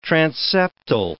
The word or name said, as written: transeptal